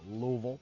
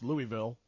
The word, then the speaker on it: Louisville: Rick Pitino, in all likelihood, gone as the head coach at the University of Louisville, as they say down there in Louisville. It's just a two-syllable word, Louisville. For the rest of us, it's Louisville.